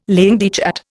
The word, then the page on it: langue de chat: Indian